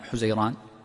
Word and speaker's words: حزيران: على قدم وثلث في نصف حزيران. – نعم يقول يكون طولها قدم وثلث في نصف حزيران، أو بعضهم ينطقها بضم الحاء حزيران، وهو الشهر السادس من أشهر السنة الشمسية.